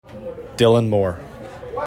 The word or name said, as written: Dylan Moore